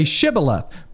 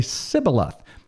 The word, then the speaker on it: Shibboleth: So they would have them say Shibboleth, but the Benjamites couldn't frame to pronounce it, and they would say Sibboleth.